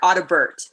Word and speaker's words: Audibert: I say the T hard, Audibert.